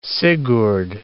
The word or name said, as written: Sigurd